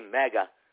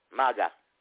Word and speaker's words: MAGA: Every time they say mega, MAGA, they don't even know how to pronounce it.